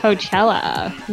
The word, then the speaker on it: Coachella: Coachella. Well, is that how you pronounce it? – that, yeah, that's how you pronounce it in Coachella Um, it was.